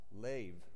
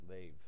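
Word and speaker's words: lev (לב): So in Hebrew, the word heart is lev, spelled L-E-B, but pronounced lev.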